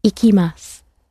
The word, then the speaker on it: ikimasu: The verb to go ends again in mas, and it is ikimasu. – Ikimasu. – And if you want an image for remembering that, I'm picky about where I go. So it rhymes with picky, iki, ikimasu.